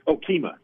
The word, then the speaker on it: Okemah: Kema Rising, is that how you pronounce it? Okemah. – Oh, Okemah. Oh, yeah.